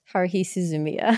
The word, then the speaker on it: Haruhi Suzumiya: Haruhi Suzumiya? – Haruhi Suzumiya.